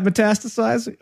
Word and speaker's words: metastasizing: Is that metastasizing? Okay, you're right. – Yeah. – Yeah, right. What did I say? Metacizing? Yeah. Metastasizing.